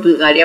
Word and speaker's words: Bvlgari: बुल्गारी, now I know that it's called बुल्गारी, I mean I have known that for a while now. It's spelt as B-V-L-G-A-R-I.